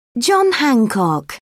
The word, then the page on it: john hancock: British English